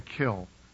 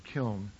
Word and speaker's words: kiln: because they become basically rock when they're fired in a kiln, or kiln, pronounced either way.